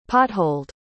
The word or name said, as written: potholed